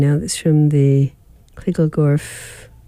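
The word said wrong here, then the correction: Kisselgof: I keep saying it wrong. Kissel Goff Archive.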